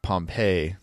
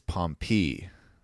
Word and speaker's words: Pompey: And by it, it's not Pompeii, like the city in Italy that was buried by a volcano. It's Pompey. And I didn't know that.